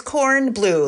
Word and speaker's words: Cornblow: um, it, am I pronouncing that one right? Um, it's co- it's corn blue, like the vegetable and the color.